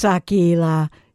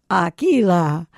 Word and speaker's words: Aquila: Aquila? No, it's Aquila. She knows I like to say her name, Aquila. Kind of rhymes with tequila.